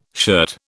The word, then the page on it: shirt: British English